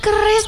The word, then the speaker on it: QRIS: Ibu mana punya keris toh, Den? Emangnya orang-orang punya keris? Bisa ya bayar pakai keris? Kok malah kayak mau bela diri aja? – Keris, Bu. Keris. Q-R-I-S. Dibacanya keris bukan keris.